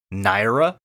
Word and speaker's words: Naira: Naira? – I may be mispronouncing it, but it's okay. – N-A-I-R-A, Naira. – Sounds like Naira.